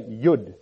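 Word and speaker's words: yod: But verse 73, you'll find J-O-O-D, or J-O-D, just above verse 73 there. And in Hebrew, you'd say yud. That's what that is, yud. And I would pronounce that J-O-O-D, but you can write it how you like. But it's not Jod, it's Yud.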